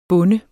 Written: bunde

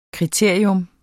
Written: kriterium

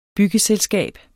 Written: byggeselskab

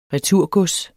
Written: returgods

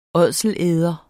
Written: ådselæder

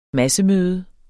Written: massemøde

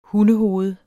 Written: hundehoved

